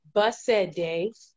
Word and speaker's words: Basade: It's pronounced Basade, but Basade. – You know, people call me Basse for short. Like a bus, say something. Basse.